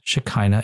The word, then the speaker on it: Shekinah: The rabbis teach us that the Word of God is referred to as the Shekhinah, pronounced Shekinah in English.